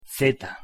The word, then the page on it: z: Spanish